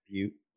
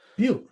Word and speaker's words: Butte: She's from Butte, Montana, I believe. – But. – What? – I think it's pronounced Butte. – Butte. – Are you sure? – I'm pretty sure.